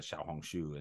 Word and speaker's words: Xiaohongshu: you know, and then later on, you know, you had Xiaomi and you had uh, Huawei, and then of course now it's it's Pinduoduo, it's uh, Xiaohongshu, and it just like Meituan, yeah, crazy, right? – Your, so, your Chinese pronunciation yeah. is perfect for these. Companies.